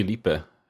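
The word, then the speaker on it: Phillippe: How do you pronounce his name? You said it. Philippe? – Philippe. I was at Philippe.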